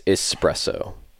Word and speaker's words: espresso: No, it is an S. I know how to spell it, but I do say it with an X. – No, it's espresso.